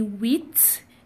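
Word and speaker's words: huit: So moving on, we have eight to be wheat. Now you may you may feel like wheat. How come? That is just the fixed pronunciation for number eight in French.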